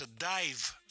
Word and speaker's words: DIIV: Называется Dive, но пишется через Double I. Да. То есть не... – D-I-I-V.